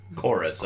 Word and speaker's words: Chorus: Another game called Corvs, I believe is how you pronounce it. It's a space combat shooter. Is it Chorus? – Chorus, I've heard it called. – Chorus, really? – Yeah. – Looks like it's spelled Corvs, though, in the title. – Yeah, you're not the only one with a mistake, dude. It's their fancy way of saying Chorus.